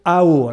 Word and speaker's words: hao: Euh, l'idéogramme chinois pour dire beauté, c'est l'idéogramme qui se prononce AO. Alors je suis désolé, je ne suis pas très bon en prononciation chinoise, mais ça s'écrit H-A-O en, alphabet euh, de chez nous.